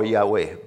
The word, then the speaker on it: Yahweh: which is usually pronounced today as Jehovah or Yahweh. But the real pronunciation was lost in history.